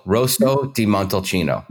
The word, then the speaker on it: Rosso di Montalcino: So it's Rosso, Rosso di Montalcino.